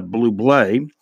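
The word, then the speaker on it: Bublé: Michael Blue Blay, it's pronounced Blue Blay.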